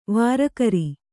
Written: ವರಕರ

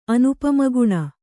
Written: ಅನುಪಮಗುಣ

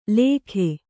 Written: ಲೇಖೆ

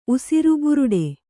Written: ಉಸಿರುಬುರುಡೆ

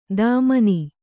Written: ಧಮನಿ

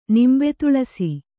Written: ನಿಂಬೆತುಳಸಿ

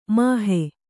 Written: ಮೋಹ

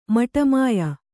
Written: ಮಟಮಾಯ

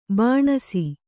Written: ಬಾಣಸಿ

